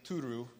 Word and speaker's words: Turu: And it's because there is a group of people there, a tribe named the Turu. Did I say it right? You said it perfect.